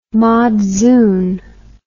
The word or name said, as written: madzoon